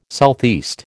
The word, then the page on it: SE: American English